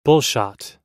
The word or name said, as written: bullshot